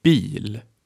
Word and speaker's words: bil: Say after me, bil.